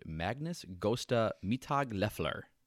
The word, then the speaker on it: Magnus Gösta Mittag-Leffler: It actually took some advocacy from a Swedish mathematician named Magnus Gösta Mittag-Leffler.